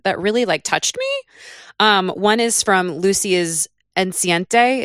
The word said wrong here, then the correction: enceinte: Is that how you say it? – Enceinte. It's French.